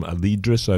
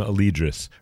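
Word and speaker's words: Alydrus: That's A-L-Y-D-R-U-S. Adam Alidrus, I believe is the pronunciation.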